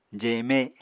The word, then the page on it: jeme: apatani